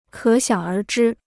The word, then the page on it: 可想而知: kě xiǎng ér zhī